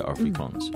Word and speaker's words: Afrikaans: 嗯，那南非另外一个比较普及的语言就是 Afrikaans 啊，就是嗯用哎。这个 Afrikaans 就是有点像是 African，、嗯、但是用呃这个荷兰的发音叫 Afrikaans、